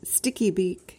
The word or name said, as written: stickybeak